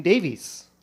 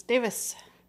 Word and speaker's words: Davies: I got to keep remembering Davis, Davis, Davis. – I've heard in Davies in this country, but the Welsh pronunciation is Davis.